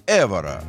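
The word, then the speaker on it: Évora: В названии города Эвора ударение на первую букву. А, Эвора.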